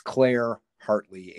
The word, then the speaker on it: Heartley: Her name is spelled H-E-A-R-T-L-E-Y. So it's Claire Hartley, H-E-A-R-T-L-E-Y.